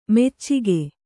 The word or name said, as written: ಮೋಚಿಕೆ